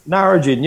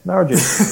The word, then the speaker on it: Narrogin: Did you pronounce that right? – Narrogin, yeah, Narrogin. It's N-A-R-R-O-G-I-N, Narrogin.